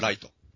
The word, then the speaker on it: 月: あ、本名はライト。 – ライトです。 – ライトだけど、月と書いてライトなんですよ。 – 月と書いてライト。 – それが、あの、殺すとキラッと輝くのとをかけてキラなんですよ。 – 月と書いてライトってのも大概やね。 – 大概よ。 – その人が、あの、ペンライト振ってるところからはドラマ始まったんですよ。ライトとペンライトかけてたんです、あそこ。